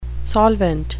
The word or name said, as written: solvent